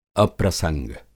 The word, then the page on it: अप्रसंग: Hindi